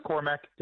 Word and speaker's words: Cormac: Just Cormac.